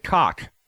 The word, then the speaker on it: Koch: And Dr. Doug Koch, spelled K-O-C-H, but pronounced how I said it.